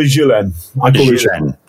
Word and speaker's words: Ghislaine: I can never say her name properly. Hunting Ghislaine. – I call her Ghislaine.